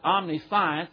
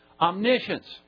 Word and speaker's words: omniscience: But you don't call it omniscience. It's pronounced omniscience.